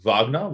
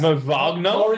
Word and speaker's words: Wagner: Pronounce it Wagner. Let's pronounce it correct. – It's Moritz Wagner. Moritz Wagner.